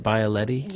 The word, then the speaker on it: Bialetti: Did I say that right, Bialetti? – Yes.